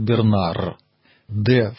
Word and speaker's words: Бернар: Поэтому, конечно же, если мы будем произносить его имя по-французски, на современный манер, то, конечно, это будет Бернар. «Д» в конце во французском языке не читается. Но поскольку это было всего лишь начало второго тысячелетия, время, когда французский язык только становился, а сами схоласты писали и часто и говорили на латыни, имя-то латинское, древнеримское, Бернард.